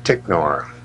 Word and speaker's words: Ticknor: And T stands for Ticknor. – So would you spell that for me, please? – T-I-C-K-N-O-R.